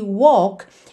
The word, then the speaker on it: walk: Lembrando sempre que walk é uma palavra que engana um pouquinho, porque esse L não vai ser pronunciado como L mesmo. A gente não leva a língua lá no céu da boca walk, e sim walk walk, ok?